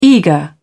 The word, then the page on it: eager: British English